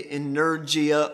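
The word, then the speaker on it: energeia: Strong's tells us that the Greek word for it is, if I can pronounce it, energia. Energia. Think energy, okay?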